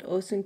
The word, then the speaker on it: Osun: One of my first questions was that on Instagram you called Osung Child. Does it have any special meaning? – Yes, it's pronounced Osun. Yes, it's a Yoruba.